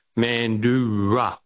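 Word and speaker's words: Mandurah: It's an indigenous name, and it's pronounced Mandura.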